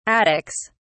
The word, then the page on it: attics: British English